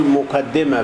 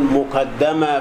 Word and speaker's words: مقدمة: كتاب مقدمة في أصول التفسير، إلا هذا الكتاب، كتاب مقدمة في أصول التفسير، بعض العلماء ينطق يقول مقدمة بكسر الدال، وآخرون يقولون مقدمة في أصول التفسير بفتح الدال.